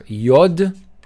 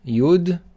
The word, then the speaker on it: Yud: It's pronounced either Yod or Yud. It doesn't matter, it's both the same.